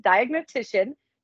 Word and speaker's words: diagnostician: She is a plant disease diagnostician. Did I say that right, Colette? That is a tough word. – Yes, it's good.